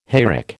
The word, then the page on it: hayrick: American English